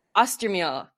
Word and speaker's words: Austermehle: Is that how I pronounce Austermehle. – It? – Austermehle.